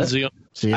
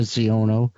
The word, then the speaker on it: Xiono: X-I-O-N-O. – Kazuda Ziono. I've heard Ziono. – Yeah, that's what I thought. New character named Kazuda Ziono.